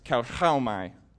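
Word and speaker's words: kauchaomai: It's pronounced kauchaumai. And I want all of us to say that together. So say cow like a moo. Cow. – How. – How. My. – Cow, how, my.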